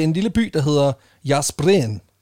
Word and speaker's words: Jászberény: Det staves J-A med accent S-Z-B-E-R-E med en accent grav N og så Y, men udtales Jászberény.